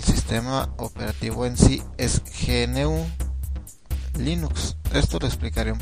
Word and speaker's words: GNU: qué significa GNU, es Is Not Unix y se pronuncia igual a New.